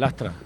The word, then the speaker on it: Lastra: con la presencia de Adriana Lastre. – Sí, el Lastra. – Perdón, al final lo digo mal.